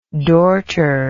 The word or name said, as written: dorter